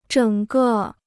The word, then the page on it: 整个: zhěng gè